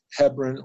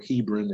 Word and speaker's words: Hebron: Now, I want to say a word about Hebron or Hebron. It's pronounced both ways.